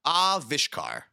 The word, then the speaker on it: Avishkar: The accent's on the first syllable, yes. Avishkar.